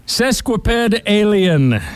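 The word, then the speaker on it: sesquipedalian: I guess that's a horrible pronunciation. – It is? – How is it said correctly? – Sesquipedalien.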